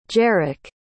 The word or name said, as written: jeric